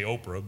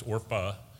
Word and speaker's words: Orpah: You always want to read it and say Oprah, but it's not. It's Orpah and Ruth.